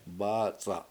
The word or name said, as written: bAAc7a7